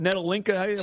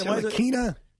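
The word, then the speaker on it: Ntilikina: Nettolinka? – Tillakina.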